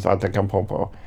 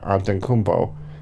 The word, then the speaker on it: Antetokounmpo: It's Giannis Antetokounmpo. I totally mispronounced that. Antetokounmpo. There we go. That's better.